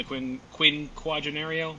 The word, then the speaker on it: Quinquagenario: And then like everyone's Aaron and Coop said, Volstead Act and the Quinquagenario, am I pronouncing that right? – Ooh, well done.